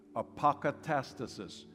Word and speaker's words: apokatastasis: You pronounce it this way, apokatastasis.